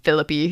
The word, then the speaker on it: Phillippe: So, Ryan Phillippe, that's another thing, is that apparently his last name is pronounced Phillippe. – Not Phillippe? – I've always thought it was Phillippe, and then I was watching an interview with him, and somebody called him Ryan Phillippe, and he's like, actually, it's pronounced Phillippe.